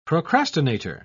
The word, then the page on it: procrastinator: British English